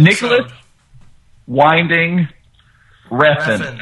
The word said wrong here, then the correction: Winding: Actually, I just have to step in here. It's actually pronounced Nicholas Vinding Refn.